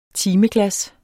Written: timeglas

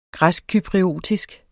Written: græskcypriotisk

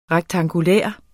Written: rektangulær

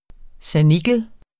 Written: sanikel

